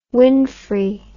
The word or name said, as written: winfrey